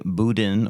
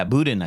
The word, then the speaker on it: boudin: Well, it looks like it's spelt one way, but they say boudin or boudon. You have to have a real you need a real thick Louisiana accent to get it right.